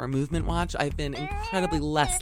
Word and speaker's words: MVMT: It's pronounced movement, sort of like MGMT, but for time telling.